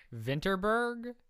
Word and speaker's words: Vinterberg: Vinterberg. – Vinterberg.